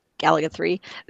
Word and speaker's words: Gaplus: Gaplus, or you could call it Galaga 3. Yeah, it looks like Gaplus, but you actually pronounce it Galaga 3.